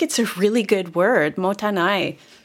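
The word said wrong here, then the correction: mottainai: Am I saying it right? Yeah, motainai. Motainai.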